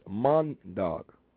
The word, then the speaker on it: Maandag: Monday is Mondag. – Mondag. – And that's spelled M-A-A-N-D-A-G.